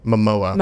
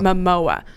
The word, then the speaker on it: Momoa: Jason Momoa. – Momoa, mm-hmm.